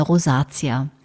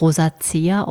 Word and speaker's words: Rosacea: Diese Beschwerden beschreiben eine Rosatia. Die Rosatia, teilweise auch als Rosacea ausgesprochen, hat noch viele weitere Bezeichnungen.